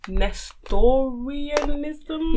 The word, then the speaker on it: Nestorianism: Nestorianism.